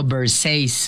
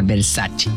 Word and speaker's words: Versace: Por ejemplo, Versace, cuando la marca Versace es italiana, entonces tiene que pronunciar Versace.